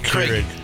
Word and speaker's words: Keurig: Krieg. – Keurig. – Keurig. – Keurig. Yeah. You don't even know how to say it.